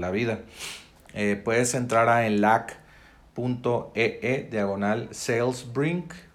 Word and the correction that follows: SalesBlink: Digo salesblink, se escribe s a l -E s b l i n k y bueno, puedes entrar a enlac.ee e diagonal sales blink para aprovechar esta aplicación y oferta.